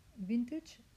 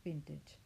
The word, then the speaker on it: vintage: Vintage se pronuncia vintage y no se pronuncia, aunque suene muy cool o muy francés o muy chic, ya que tiene la terminología esta del acento este de los franceses, no se pronuncia vintage, que es lo que oímos todo el día en la calle.